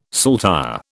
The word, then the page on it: saltire: British English